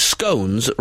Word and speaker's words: scone: Tina, who's ironing and listening in sunny Lincoln, said, regarding scone, in our house, it is scones rhyming with bones. Until it's eaten, then it's scone.